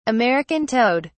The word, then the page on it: american toad: British English